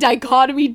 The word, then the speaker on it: dichotomy: He said the dictomy of leadership. And I called him on it instantly. I was like, you mean dichotomy, dumbass?